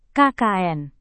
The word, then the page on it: kkn: indonesian